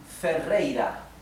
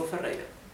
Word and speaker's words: Ferreira: So this is Rodrigo Ferreira. Let me separate in syllables. Ro, dri, go. Fe, rei, ra. Okay, Rodrigo Ferreira.